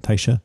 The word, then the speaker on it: Tysha: Tysha? – Yep.